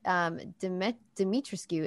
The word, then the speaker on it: Dimitrescu: And we have all been corrected, especially, like, when we did it on Trailer Talk, that Dimitrescu is technically how it's supposed to be pronounced, because it is Bulgarian, I want to say. Somebody from Bulgaria, anyway, like, commented on the YouTube channel. They were like, hey, if you're going to use an Eastern European name, please pronounce it correctly. It is. And they spelled it phonetically as Dimitrescu.